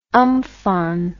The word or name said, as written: umfaan